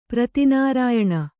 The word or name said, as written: ಪ್ರತಿನಾರಾಯಣ